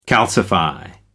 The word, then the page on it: calcify: American English